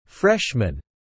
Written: freshman

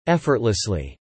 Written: effortlessly

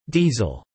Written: diesel

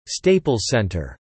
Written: Staples Center

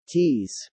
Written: tease

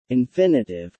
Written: infinitive

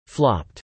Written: flopped

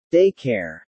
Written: day care

